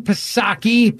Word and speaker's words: Psaki: Here's former White House Press Secretary Jen Psaki, P is silent for panic, saying members of Congress and Democratic operatives are asking, what do we do now?